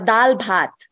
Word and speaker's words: dal bhat: Uh, dal bhat.